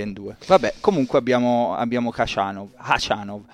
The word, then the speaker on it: Khachanov: Cioè, Khachanov in cognome inizia con la X e invece il nome è Karen con la K. E quindi la pronuncia corretta in russo, come avete sentito, è differente.